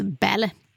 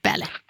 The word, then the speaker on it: Balle: Ja, och Solveig heter, det stavas B-A-L-L-E, men uttalas Belle. Belle.